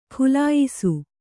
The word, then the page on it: ಖುಲಾಯಿಸು: Kannada